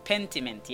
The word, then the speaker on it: Pentiment: My number one is a game called Pentiment? Am I saying that right? – Pentiment, yeah.